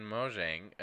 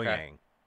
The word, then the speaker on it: Mojang: Uh, today, um, Microsoft and Mojang announced that Minecraft. Mojang. – Mojang. – That's how they say it.